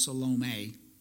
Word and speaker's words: Salome: When the Sabbath was passed, Mary Magdalene, Mary, the mother of James and Salome, if you want to get technical, that's pronounced Salome, bought spices so that they might go and anoint him.